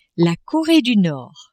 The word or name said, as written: Coree du Nord